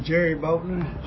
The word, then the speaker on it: Jerry Boatner: I'm Jerry Boatner, J-E-R-R-Y, Boatner, B-O-A-T-N-E-R.